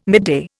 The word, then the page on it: midday: Indian